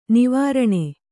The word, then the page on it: ನಿವಾರಣ: Kannada